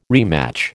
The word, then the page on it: rematch: American English